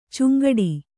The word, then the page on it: ಚಂಗಾಟ: kannada